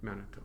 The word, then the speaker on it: Manitou: And the Cree word for God is pronounced, depending on where you're from, mostly it's just like Manitou, mm-hmm. right? Sometimes it's shortened, sometimes it's lengthened.